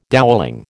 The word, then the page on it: dowelling: American English